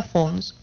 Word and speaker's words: Fones: Cristóbal Fones, aunque se diga Fons, porque se escribe Fones.